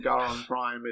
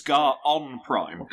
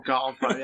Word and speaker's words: Garon Prime: Like, it's not Garon Prime, it's like Prime. – No, it's Garon Prime. – Garon Prime, yeah. – You get a roll the R.